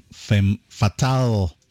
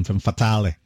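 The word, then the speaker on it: Femmes Fatales: Well, you know, in French, you don't pronounce the S, so that's why I pronounce it exactly the same way. Femme Fatale and then Femme Fatale.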